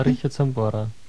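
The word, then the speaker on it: Richard Sambora: Herr Richard Zambora. Habe ich das richtig ausgesprochen? – Ja, Richard Zambora.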